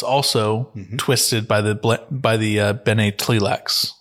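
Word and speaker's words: Tleilax: Plus their relationship with the Bene Tlilax, the Tlilax pronunciation comes from Frank Herbert's own mouth, from the Dune Trilogy soundbook.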